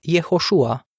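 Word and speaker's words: Iehoșua (יְהוֹשֻׁעַ): Creștinii din secolul I care vorbeau ebraica trebuie să-i fi pronunțat numele Iehoșua, iar cei care vorbeau greaca, Iesus.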